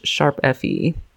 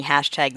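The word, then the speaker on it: ♯FE: That's how you pronounce it. This is what I've heard that this is how you pronounce it. And I too have been saying hashtag this whole time. So if you are like me, do not feel sad or guilty. – No, it's a sharp.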